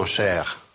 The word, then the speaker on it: chère: В женской форме добавляется «э», но читается все равно «cher». Читается одинаково. «mon cher ami» и «ma chère amie».